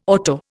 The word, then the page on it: auto: Indian